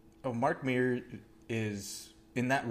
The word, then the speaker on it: Mark Meer: Mark Mears? – I'll give it to you. No S. – Mark Meer. – Mark Meer.